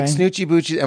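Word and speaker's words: Snootchie Bootchies: But with like Snoochie Boochie, am I saying that right? – Yes.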